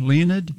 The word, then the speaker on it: Leonid: Is it like the meteor shower, Leonid? – Yeah, it is. Okay. That's probably the way to pronounce it, too. L-E-O-N-I-D.